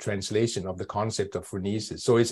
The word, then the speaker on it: phronesis: And you talk about in the book about Aristotle and his idea of phronesis, if I pronounced that correctly.